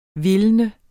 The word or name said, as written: vildt